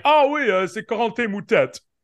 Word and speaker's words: Moutet: I'm like 90% sure that his name is probably pronounced Moutet. – No, it's Moutet.